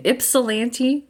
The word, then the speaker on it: Ypsilanti: Today, we are delighted to have Jenny Fisher from Eastern Michigan University in Ypsilanti, Michigan. Did I say it right? – Yes, you did.